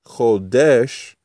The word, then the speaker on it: Chodesh: And the word Hadash, if you change the vowel points, is Chodesh, which is how you say month.